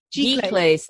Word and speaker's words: giclée: How do you say that word? Is it glycis or Giclées.